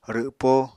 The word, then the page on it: rwpo: Apatani